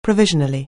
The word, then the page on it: provisionally: anglais